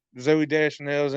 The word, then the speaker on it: Zooey Deschanel: Zooey Deschanel's in it.